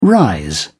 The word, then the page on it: rise: British English